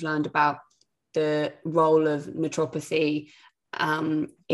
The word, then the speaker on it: naturopathy: that natural how do you say it naturopathy naturopathy naturopathy there you go um, i've yeah.